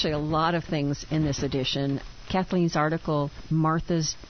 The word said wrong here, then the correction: Marta: I think it's Marta, is how it's said, journey.